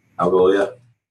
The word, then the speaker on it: Algolia: So they suggest using a third-party tool called Algolia or Angolia. I can't remember how it's pronounced. – Algolia.